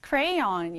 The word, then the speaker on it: crayon: 이 단어는 우리가 발음을 하게 되면 crayon 이렇게 발음을 하게 됩니다. 자, 크레용이지만 영어에서는 N으로 끝나기 때문에 발음에 주의해 주셔야 합니다. 쓸 때는 C, R-A-Y-O-N 이렇게 써주면 crayon이라는 단어가 됩니다.